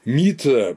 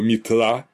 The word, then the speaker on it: Митра: Слово «митра», а в отличие от принятого в Иране и потом, естественно, в европейском мире ударения, в Индии чаще, особенно в ведический период, ударение «митра», а не «митра».